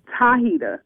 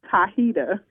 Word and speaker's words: Tahita: Tahita. – Hi-hita? – Yes. – Am I pronouncing that right? Hi-hita? – Tahita with a T. – Oh, right. Hi-hita. All right, baby. – Tahita.